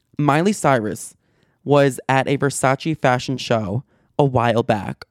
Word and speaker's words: Versace: I pronounced that right. Thank you very much. Versace.